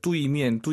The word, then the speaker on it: 对面: 뚜이미엔, 뚜이미엔. 선생님, 알아듣죠? – 能听懂. – 네, 안 예뻐요. 다시 한 번. – 뚜이미엔. – 뚜에이, 뚜이미엔. 빠르게 발음하시면 뚜이로 들리긴 하죠. 뚜에이, 정확히 발음하셔야 됩니다.